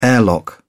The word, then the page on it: airlock: British English